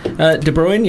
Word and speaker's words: De Bruyne: De Bruyne, yeah.